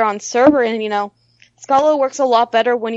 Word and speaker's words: Scala: It is the first time I've ever seen anybody really commit to Scala, but or Scala, however you pronounce that language. But Scala, yeah. Wow.